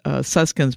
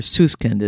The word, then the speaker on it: Susskind: Tell us what you wrote about and what's your take on the futurist Richard Susskind, or Susskind is, I think, is how he likes to pronounce it.